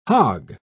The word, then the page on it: hogg: British English